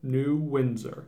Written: New Windsor

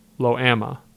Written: Loami

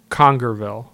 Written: Congerville

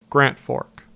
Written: Grant Fork

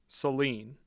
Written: Saline

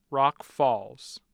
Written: Rock Falls

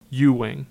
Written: Ewing

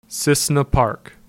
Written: Cissna Park

